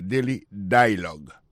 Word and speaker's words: dialogue: Daily Dialogue. Alors, accent tombé sur le premier syllabe là.